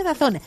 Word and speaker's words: DAZN: DAZN, pero se pronuncia Dazón.